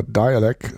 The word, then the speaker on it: Dalek: Aber es ist nicht von Boards of Canada, sondern aus New Jersey stammt Dialek, geschrieben D-A-L-E-K. Also in Deutschland wird das vermutlich sehr oft falsch als der Dalek ausgesprochen. Sie heißen aber Dialek, das Hip-Hop-Underground-Duo, das seit den 90er Jahren auch schon aktiv ist.